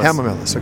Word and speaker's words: Hamamelis: Camomillus. – Or Hamamelis. – Hamamelis, okay.